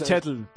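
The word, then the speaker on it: Cheadle: ¿Cómo se pronuncia Don Chadley? – Yo suelo decir Chetl, pero... – Chetl, claro. Es que a veces, claro, dices Susan Sarandon y no saben quién es Susan Sarandon.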